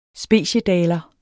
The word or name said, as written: speciedaler